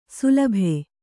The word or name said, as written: ಶಲಭ